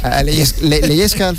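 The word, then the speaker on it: Lehečka: Leyesca, mire, lo estaba yo pronunciando mal.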